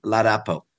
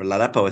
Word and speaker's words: Ladapo: This is a man called Joseph Ladapo, or Ladapo, I think is the pronunciation of his name.